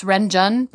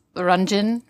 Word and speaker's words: Renjun: and at 2:34, we've got Runjin, which I always want to say it's Renjun, but it's apparently pronounced Runjin, so...